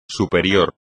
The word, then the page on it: superior: espagnol